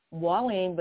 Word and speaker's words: Mwalim: It's Mwalim, but it's spelled M-W-A-L-I-M.